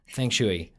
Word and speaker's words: feng shui: So F-E-N-G, feng, so like a U. – Yeah. – And shui, S-H-U-I, but pronounced S-H-O-Y.